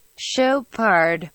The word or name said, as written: Chopard